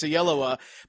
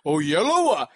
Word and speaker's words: Oyelowo: David Oyelowo, as he says, that's how his dad pronounces his last name. And doing talk shows, like, how do you pronounce your name? It's Oyelowo, but how does your dad pronounce it? Oyelowo.